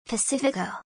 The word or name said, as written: Pacifico